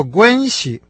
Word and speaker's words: 关系: Слово «гуанси» – первый слог произносится с первым тоном, второй – с легким.